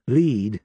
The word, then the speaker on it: Lead: Lead, South Dakota, is pronounced as is the verb, not as the malleable heavy metal.